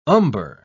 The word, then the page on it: umber: British English